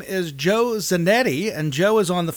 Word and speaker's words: Zanetti: It is Zanetti, yes. Okay. Zanetti is correct.